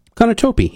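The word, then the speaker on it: Konotop: Did I say that right? Konotope in Poland? Anyway. – Yeah, Konotope, yeah.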